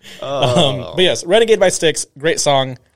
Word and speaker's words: Styx: It's a song by that, I think you pronounce it, by Styx.